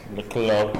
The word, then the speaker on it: Leclerc: Señor viajero, ¿pronuncié bien Leclerc? – Sí. – ¿O se pronuncia Leclerc? – Leclerc.